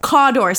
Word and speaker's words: Cawdor: Cawdor, C-A-W-D-O-R. – Oh, okay. – Cawdor. – Which throughout the course of its history is spelled three different ways, but it's all pronounced Cawdor. Okay. It's like sometimes it's C-A-U-D-L-E-R.